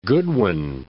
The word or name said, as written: Goodwin Sands